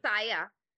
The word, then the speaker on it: Saya: Saya. Rhymes with okay. Maya.